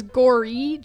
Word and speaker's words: Gorry: But yeah, my last name is Gorry, G-O-R-R-Y. And my first name is spelled with an E, L-I-N-D-S-E-Y.